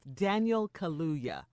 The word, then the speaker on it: Daniel Kaluuya: And I struggle pronouncing his name, but it's Daniel Kaluuya.